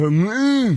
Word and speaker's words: Hormuz: Strait of Hormuz.